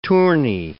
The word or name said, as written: tourney